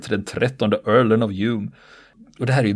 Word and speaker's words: Home: Det här namnet alltså, det stavas Home, men det uttalas mm. Hume. – Mm, som filosofen.